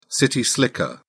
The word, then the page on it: city slicker: British English